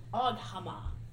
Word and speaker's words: Oghma: I'm going to say this right. Oghma. O-G-H-M-A.